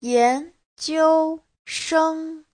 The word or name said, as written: yan2jiu1sheng1